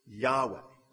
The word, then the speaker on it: Yahweh: It used to be pronounced Jehovah. Nobody really knows how to pronounce it, but in Hebrew, it's those four letters, Y, H, W, and H. And so scholars today will pronounce it Yahweh. That's probably about the best that we can do.